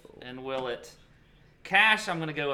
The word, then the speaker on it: Willett: I'm glad you learned to say his name right after 4 years. You said Danny Willett.